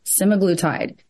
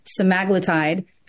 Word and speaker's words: semaglutide: So let me backtrack 1 second and say Ozempic is a name brand for a general medication, a generic medication that's called semaglutide. You may also pronounce it semaglutide. And I don't think either pronunciation is right or wrong. I'm from Kentucky, so I say I'm going to go with semaglutide because it's easier for me to say.